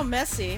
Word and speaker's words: Messi: Okay, Lionel Messi, am I saying his name right? – Yeah.